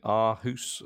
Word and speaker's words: Aarhus: I liked what the Danish side, is it Aarhus? I forgive pronunciation. – Yes.